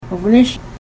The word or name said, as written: wgonesh